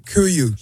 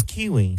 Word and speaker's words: KUIU: Kuyu. – He doesn't know how to pronounce it. – K-U-I-U.